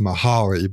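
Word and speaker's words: Mihály: It's a little tricky, but if you just Google flow, Mihai, his first name is Mihaly. It's sort of phonetically pronounced Mahali, but it's Mihai.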